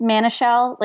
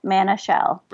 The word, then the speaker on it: Manashil: It's Manashil, like man, it's a shell, Manashil.